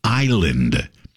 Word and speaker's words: island: The word is island, I-S-L-A-N-D, island. Silent S, island, I-S-L-A-N-D.